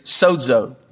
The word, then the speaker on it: sozo: The Greek word for saved is sozo.